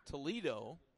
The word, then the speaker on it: Toledo: Toledo's last name spelled like Toledo, Ohio, but pronounced Toledo.